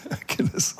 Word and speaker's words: Achilles: Well, I say Achilles, Achilles, is it? – Yes. Achilles. I don't know how you say it, but we say Achilles. Mm. In France, they call it Achille. over here, Achilles.